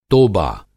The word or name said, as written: तोबा